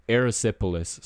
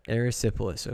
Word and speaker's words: erysipelas: Yeah, it was E R Y S I P E L A S.